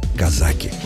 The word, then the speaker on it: казаки: Зато известно точно, что в названии знаменитой повести Льва Толстого ударение падает на второй слог – «казаки».